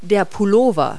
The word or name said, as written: sweater